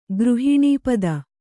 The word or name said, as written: ಗೃಹಿಣೀಪದ